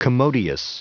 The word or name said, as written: commodious